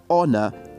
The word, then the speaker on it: honor: Please take note that words like hour, honor, air are pronounced without having to use the H as a sound. This means they will be pronounced as O-U-R, O-N-O-U-R, and E-I-R respectively.